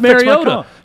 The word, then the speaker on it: Mariota: It's pronounced Mariota. You don't know anyone named Mariota, so why are you saying Mariota? It's Mariota, and it's just like his first name, Marcus.